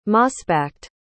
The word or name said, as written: mossbacked